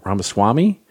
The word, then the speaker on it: Ramaswamy: And so then instead they went after, I always get his name wrong, Ramaswamy? – I think that's right. Yeah.